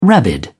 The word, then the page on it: rabid: British English